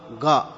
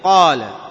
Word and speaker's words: قال: أيضا مما ينبه عليه مثلا القاف، القاف بعضهم يقول له قاء، قاء، يقول للقاف إيش؟ قاء، مثل قال، إيش قال؟ قاء، قاء. طبعا بعض القبائل نطقت به، بعض القبائل العربية في الحجاز نطقت بهذا، وأيضا في اليمن ينطقون القاف قاء فيقولون قال، ولكن الصحيح أن تقول قال، تقول ماذا قال.